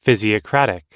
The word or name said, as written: physiocratic